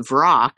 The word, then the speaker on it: Vrock: For example, you know, with the Hezrau and the food, and then when you conjure the Vrock or V-Rock, I don't care how you pronounce it, but I've always said Vrock forever.